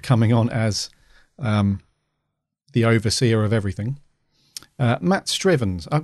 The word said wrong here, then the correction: Strevens: Is it Matt Strivens or Matt Strevens? – Strevens, I would have said. – Strevens. – Yeah. – Because it wouldn't have two E's, would it? – Strevens.